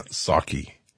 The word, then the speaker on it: Psaki: You know, I was watching press conferences over the last couple of days, Jen Psaki. Now, her name is spelled P-S-A-K-I. Remember, the P in Psaki is silent as it is silent in President Joe Biden.